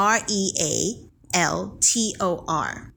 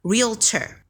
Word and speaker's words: realtor: R E A L T O R นะคะ R E A L T O R นะคะซึ่งออกเสียงได้ 2 แบบนะคะแบบแรกเลยก็คือตามสะกดเลย Realtor